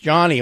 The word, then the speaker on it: Jony: I know it's spelled J-O-N-Y, but don't do some sort of sexy translation or pronunciation. It's just Johnny.